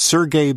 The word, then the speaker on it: Sergey: In Silicon Valley, California, the one-time Stanford students, Larry Page, and is it Sergey Brin? – Yes.